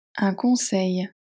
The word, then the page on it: un conseil: French